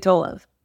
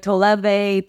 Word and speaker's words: Tolev: It is insane how no one can say Tolev. – Really? – I don't know why Americans can, like, Toleve, Tolev, like, it's...